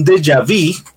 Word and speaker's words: déjà vu: Ese sería un déjà vu o un déjà vu, para pronunciarlo un poco mejor a como lo pronuncian los franceses, ¿no?